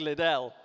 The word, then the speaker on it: Liddell: Or Lidl, how do you say it? Not Lidl, that's a supermarket. Eric Liddell, let's go with that.